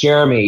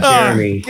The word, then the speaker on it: Jeremy: Hello, Germany. How are you? Germany? Hello, Germany. – The whole country. – Oh, my God. – Jeremy. – Yeah, fuck that intro. – Close enough. It's Jeremy. Yeah, Jeremy.